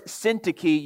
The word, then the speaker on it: Syntyche: Dear friends, verse 2 I urge Euodia, and I urge Syntyche, yes, those are correct pronunciations of those words, to agree in the Lord.